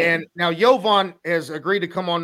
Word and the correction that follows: Jovan: Am I saying his name right? – No. Okay, it's Jovan. Jovan.